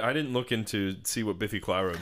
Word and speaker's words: Biffy Clyro: Oh, Biffy Clyro, that's how you say it. I've never said it out loud or heard it.